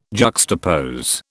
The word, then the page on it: juxtapose: British English